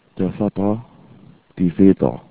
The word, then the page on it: Vater: German